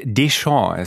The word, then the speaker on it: Deschamps: Habe ich den Dejean richtig ausgesprochen? – Ja, nee, nicht ganz. – Da, nicht ganz? Da, ich zittere hier schon die ganze Zeit. Deschamps, ist ein bisschen härter, müsstest, Deschamps. – müsstest du den aussprechen.